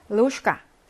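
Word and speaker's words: 로슈카 (ложка): 로슈카, 숟가락이죠. 제가 어떻게 읽었어요? 로슈카라고 읽었죠? 왜 이렇게 쥐가 셔로 바뀌었냐? 역행동화 때문에 그래요.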